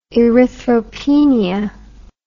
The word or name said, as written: erythropenia